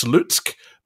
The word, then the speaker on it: Slutsk: There's a team called, I think it's pronounced Slutsk, but it's Sluts with a K at the end.